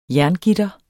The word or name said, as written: jerngitter